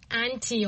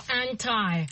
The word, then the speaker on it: anti: So the first one we've chosen is anti or anti. – Right. – How do you say that? – Anti. – Anti. – Either one is okay.